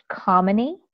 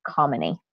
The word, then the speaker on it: Kamini: So generally speaking, I tell people to think of the word harmony or calm, and either one of those will help with the first syllable there. So it's Kamini.